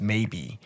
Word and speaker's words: Maeby: And the Abyssinian one name is Maybe. But it's spelt not a maybe as in like maybe, but it's spelt M-A-E-B-Y. – M-A-E-B-Y.